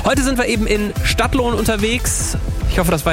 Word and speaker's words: Stadtlohn: Mit Betonung auf Stadt, Stadtlohn.